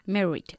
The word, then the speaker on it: married: ¿Y cómo pronunciamos married? – Married. – Married. – Married. – No married, married. – Married. – Eso es, married. Muy bien.